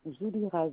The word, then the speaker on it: Zoulira: Voilà, voilà, tout simplement Omar, O-M-A-R. – Ok, et le prénom? – Zoulira, Z-O-U-L-I-R-A. – Z-O-U-L-I-R-A, si ça se prononce. Z-O-U-L-I-R-A.